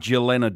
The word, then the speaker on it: Jelena: No, it's, Tex, what happens in some nationalities in the world, the J is silent, and so they say Jelena. – It's spelled J-E-L-E-N-A.